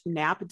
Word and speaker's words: Knapp: We have the amazing Paige Knapp. Did I even say your name? – Yep, you did. – Silent K. Correctly.